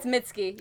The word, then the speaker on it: Mitski: it's Mitski.